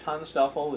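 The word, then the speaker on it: TANSTAAFL: Well, you know, Heinlein had an abbreviation which everybody pronounced Tonstoffel.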